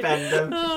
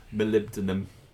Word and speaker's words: molybdenum: Molybdenum.